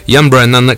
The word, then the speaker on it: Ian Brennan: A proposito di I give myself away so you can use me, Ian Brennan, che abbiamo raggiunto ed intervistato, così si pronuncia.